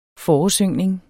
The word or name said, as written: foresyngning